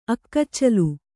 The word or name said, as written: ಅಕ್ಕಚ್ಚಲು